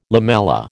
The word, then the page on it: lamella: American English